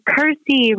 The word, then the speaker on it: Kirsty: I have always kind of had the word thirsty close to me because as any Kirsties out there in the world know, you have to explain how to pronounce our name. So we're always like, Kirsty rhymes with thirsty.